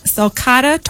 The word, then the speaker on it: sulcata: So the suspect, a nearly 100-pound, hopefully I'm saying this right, sulcata tortoise? – Oh, yeah. That's it. Exactly.